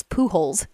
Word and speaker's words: Pujols: But it's Pujols. That guy. – It's really, you really don't have to pronounce it that way. It really so you're supposed to pronounce it. – Albert Pujols.